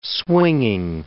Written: swinging

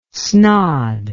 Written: snod

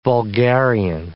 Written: vulgarian

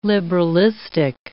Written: liberalistic

